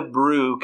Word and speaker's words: Brieux: This week's Brewery Visible take us to the banks of the mighty Mississippi to Brew Carie Brewing Company, which brew is spelled B-R-I-E-U-X, because that's how you do it in Louisiana.